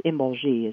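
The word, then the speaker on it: Imbolc: Then it moves on to one of the major Sabbats, which is pronounced different ways, but it's Imbolgi is what it is, and that's in February.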